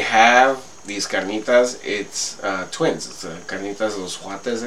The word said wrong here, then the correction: cuates: How do you say twins again? – Yeah, cuates.